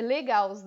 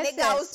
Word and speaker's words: Legaus: Então se escreve, é o L, é Legaus né? L-E G A Ai, gente, é Legaus de Gauss. Agora que ela falou que é de Gauss, é Legaus.